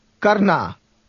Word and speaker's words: करना: And in to do, it's at the end. – Karna.